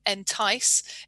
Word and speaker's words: Entyce: And I may have been pronouncing some of these words incorrectly because it's not something available to me, but I'm pretty sure Entyce is pronounced as I've said it.